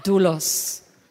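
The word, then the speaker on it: Doulos (δοῦλος): How do you say that? just a second, my friends. Friends, this is one of the first times that we will have it pronounced in the right way. Tell me, doulos. He's laughing so hard now. Okay. Just think about something very serious, and then you tell us how it's pronounced. Doulos. Doulos, something like that.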